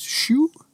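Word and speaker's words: Xu: Zhu, which is pronounced shu. – Shu. – Shu. – X-U, shu.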